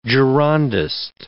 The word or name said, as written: Girondist